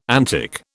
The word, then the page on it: antic: British English